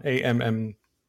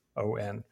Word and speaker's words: Ammon: I think it's pronounced Ammon, Ammon.